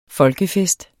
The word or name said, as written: folkefest